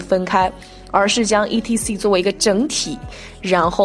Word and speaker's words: etc.: 那还有我们常见的，比如说，呃，在写作啊，或者是在给别人发简讯的时候，想要写“ 等等” 啊这个词来表达的话，我们通常会看到 e t c，对不对？大家这个时候不要，嗯，非常秀逗的一个字一个字的念啊，它正确的发音是 etc，etc，它其实也是拉丁文啊，etc 的缩写，相当于是 and so on or。